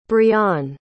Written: briann

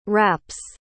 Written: raps

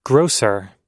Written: grosser

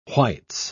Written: whites

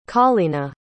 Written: Collena

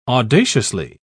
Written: audaciously

